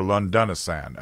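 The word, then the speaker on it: Londonistan: In 2006, Melanie Phillips published Lundunsian, or Lundunisan, excuse me, I mispronounced that, How Britain is Creating a Terror State Within, which is what we're doing.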